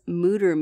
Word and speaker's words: Mütter: It's supposed to be like pronounced with an E-O sound, but they say we're happy if you say Mütter like scooter.